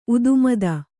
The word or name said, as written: ಉದುಮದ